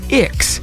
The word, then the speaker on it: Ix: The name is pronounced Ix, as in the planet Ix from Dune.